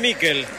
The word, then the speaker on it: Miquel: ¿Michael o Miquel? Usted que es especialista en nombre. Se escribe Miquel. ¿Cómo le digo? – Decile Miquel.